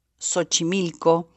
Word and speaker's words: Xochimilco: Em, esta sh se sigue pronunciando en muchísimos de nuestros vocablos como mm, Xochitl, Xochimilco, eh, Xochitepec, que más Xochi quiere decir flor, eh, y no Xochimilco o Xochitepec o, Jojo, sino Xoxo, en fin.